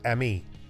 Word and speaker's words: AMIS: Jay serves on the board of trustees of the Association for Music in International Schools, AMIS, pronounced ME.